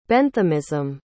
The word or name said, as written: Benthamism